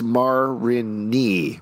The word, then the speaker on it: Marigny: I live in the, okay, I'm going to read ahead, a Marigny, pronounced Marigny, Marigny, okay.